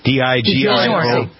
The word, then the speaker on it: DiGiorno: DiGiorno.